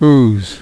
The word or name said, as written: ooze